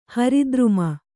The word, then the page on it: ಹರಿದ್ರುಮ: hari druma